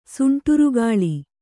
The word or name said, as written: ಸುಂಟರಗಾಳಿ